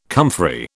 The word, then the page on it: comfrey: British English